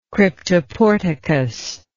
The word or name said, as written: cryptoporticus